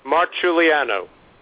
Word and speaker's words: Marciuliano: Now, Francesco, how do you pronounce your last name so the audience will get it right? Being named Mazzella, I often get Mazzola, so I don't want to butcher your name. – No problem. As I said, it took me about a good 6 or 7 years to get it right as well. It's Francesco Marciuliano.